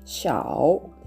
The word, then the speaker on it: 少: シャオですね。えー、えー、と第 3 声になりますので、しっかりと低く発音してみてください。